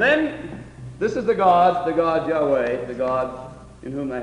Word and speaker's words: Yahweh: And if you go back now we go back and say, well, what was the real pronunciation? And so forth, it was Yahweh.